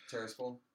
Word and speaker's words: Tiraspol: Tiraspol.